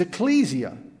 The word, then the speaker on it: ekklesia: It is ecclesia. E-K-K-L-E-S-I-A.